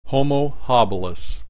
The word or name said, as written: Homo habilis